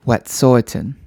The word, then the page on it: Wetʼsuwetʼen: English